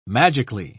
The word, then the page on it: magically: British English